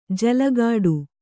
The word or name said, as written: ಜಲಘಡಿ